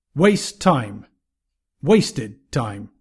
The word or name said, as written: waste time